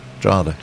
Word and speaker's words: Drogheda: Is that how you pronounce it? – Spell that. – Drogheda. – Drogheda. I was born there.